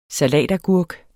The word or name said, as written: salatagurk